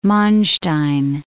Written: Manstein

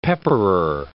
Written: pepperer

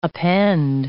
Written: append

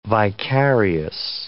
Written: vicarious

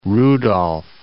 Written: Lake Rudolf

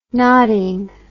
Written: nodding

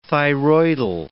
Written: thyroidal

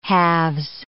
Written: halves

